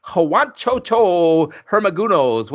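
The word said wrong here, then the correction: Juancho Hernangómez: what was this? I can't say his name. – Juancho Hernan Gomez.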